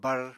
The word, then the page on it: barr: Apatani